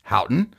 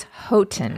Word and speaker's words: Houghton: In our recent episode about Isle Royale, we mispronounced the town of Houghton. I pronounced it Houghton. – A person from Michigan wrote in and said it is Houghton.